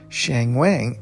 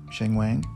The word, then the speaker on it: Sheng Wang: But he was introduced as Shang Wang, and I have to be cool with that because obviously it's not up to me to say how anybody's name should be pronounced.